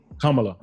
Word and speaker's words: Kamala: Make sure I'm pronouncing Kamala.